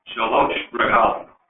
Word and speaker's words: Shalosh Regalim: And I'm sure if there was a rabbi here, he would say, you pronounce that exactly right, Shalosh Regalim.